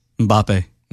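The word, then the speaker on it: Mbappé: Mbappe. – Mm-hmm. – Am I saying it right? – Yeah, you are.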